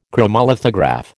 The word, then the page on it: chromolithograph: American English